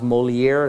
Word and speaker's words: Molière: I think the bottom is, or the top is Molière. That's how you pronounce it.